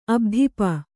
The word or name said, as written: ಅಭಿತಾಪ